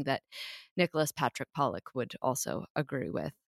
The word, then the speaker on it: Padraic: Especially if it's spelled like in the Irish way. P-A-D-R-A-I-C. Patrick.